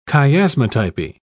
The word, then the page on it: chiasmatypy: British English